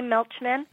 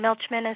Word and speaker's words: Milchman: Okay, so my website would be my name, which is Jenny Milchman, and Milchman is M-I-L-C-H-M-A-N, and I'm so appreciative that you pronounced it correctly, because I went through school hearing Milkman and all that.